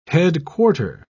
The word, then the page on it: headquarter: British English